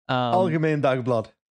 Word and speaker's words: Algemeen Dagblad: he writes in Algemeen Dag. Is that what it is? Algemeen Dag. – Close enough. – I bet you I pronounced it right. Dagblad. – Algemeen Dagblad.